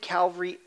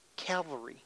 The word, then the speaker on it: cavalry: So this is a mounted cavalry, how do you say that word? Cavalry. I said it right.